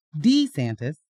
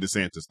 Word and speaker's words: DeSantis: And the reason we're saying his name like that now is because we've heard him say it like that. – For the very first time. – Yeah. We were saying DeSantis the whole time.